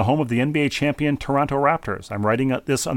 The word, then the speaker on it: Toronto: And as we were frequently reminded during the finals, if you're from Toronto, you don't pronounce the second T. It's Toronto, generally.